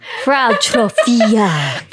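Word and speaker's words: Frau Troffea: And basically, one day, this woman named Frau Trophie. – Wait, you got to do my pronunciation. Frau Trophia.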